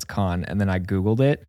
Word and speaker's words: Cannes: But I just discovered the film festival is pronounced con. – No. – Yeah. – It's not Cannes? – It's not Cannes. It's French. Con.